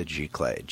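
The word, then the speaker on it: giclée: But in the grammar of French, that which is sprayed, the direct object, that which is sprayed by the nozzle, is a gicler, G-I-C-L-E-E, with an accent, rising accent on the first E.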